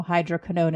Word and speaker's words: hydroquinone: And David and I always talk about not using hydroquinone, and there is no hydroquinone in that,